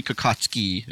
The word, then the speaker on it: Kokocki: and they tell me I say my name incorrectly. – Ah. – It should be Kokocki. It's a Polish name.